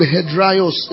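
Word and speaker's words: hedraios (ἑδραῖος): That word is the word hedraios in the Greek. It means be firm. H-E-D-R-A-I-O-S.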